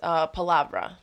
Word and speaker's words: Palavra: How do you say your last name? – Yes. Palavra.